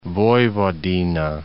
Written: Vojvodina

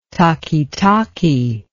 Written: takitaki

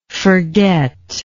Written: forgetter

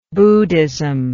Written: buddhistically

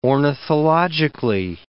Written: ornithologically

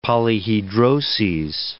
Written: polyhedroses

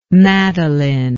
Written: madalyn

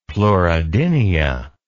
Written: pleurodynia

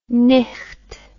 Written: nicht

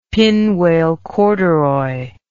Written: pinwale corduroy

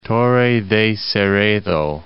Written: Torre de Cerredo

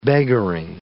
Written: beggaring

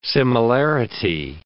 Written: similarity

